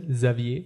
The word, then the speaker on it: Xavier: Ich würde ich es französisch aussprechen. – Dann bitte. Ja, also, Xavier. Xavier, würde ich sagen. Xavier Dolan.